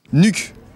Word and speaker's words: Nuuk: Capitale qui s'écrit N-U-U-K. – Nuuk.